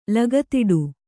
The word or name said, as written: ಲಗತಿಡು